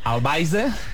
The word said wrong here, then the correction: Alvise: Eso es correcto. Bastante mal pronunciado, pero es correcto. – Al Pérez. – Alvise Pérez.